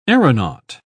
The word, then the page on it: aeronaut: English; British English